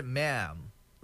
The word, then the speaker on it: mem: So it's pronounced as, oh, it's spelled as M-E-M.